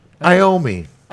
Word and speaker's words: Iommi: Black Sabbath's guitarist, Tony Amoni. I pronounced it, I'm sorry. Hey, I grew up with Black Sabbath. – Tony Iommi. Yeah, but you know Tony Iommi.